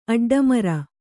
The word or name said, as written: ಅಡ್ಡಮೋರೆ